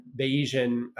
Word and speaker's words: Bayesian: But you also kind of differentiate Recast a little bit, talking about kind of Bayesian, sorry if I'm pronouncing that correctly. – You nailed it.